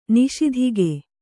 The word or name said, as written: ನಿಸ್ತೂಕ